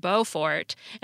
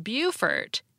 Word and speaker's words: Beaufort: And Shecky X told me there are towns in North Carolina and South Carolina that are spelled the same, but the North Carolina town is pronounced Beaufort, and the South Carolina town is pronounced Beaufort, even though they're named after the same person who apparently pronounced his name Beaufort. So, not like either of the cities.